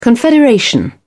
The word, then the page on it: confederation: British English